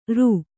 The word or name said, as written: ಱ